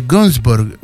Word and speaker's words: Günzburg: Saludos al diputado y a usted, Francisco, desde Günzburg, se dice así, perdón, yo me equivoqué, perdón por mi alemán pésimo.